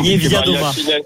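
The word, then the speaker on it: Niewiadoma: Nieviadoma.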